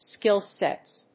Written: skillsets

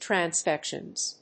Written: transfections

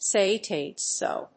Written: say it ain't so

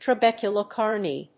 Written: trabeculae carneae

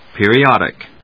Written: PERIODIC

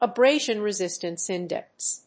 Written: abrasion resistance index

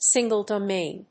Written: single‐domain

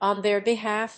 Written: on their behalf

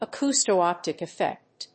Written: acousto‐optic effect

